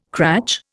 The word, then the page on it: cratch: Indian